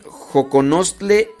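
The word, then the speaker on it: xoconostle: Dice, mm, le dicen Joconostle y la X se pronuncia como Che.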